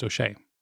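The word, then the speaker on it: Ó Séaghdha: For those of you who don't know me, my surname is spelled O Father, S E Father A, G H D H A, and it's pronounced O'Shea.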